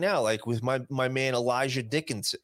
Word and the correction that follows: Elijah: I found out today his name is spelled like it's Elijah, but his name is Eli. It's weird how it's spelled. His name is that, and it's that Eli Dickin.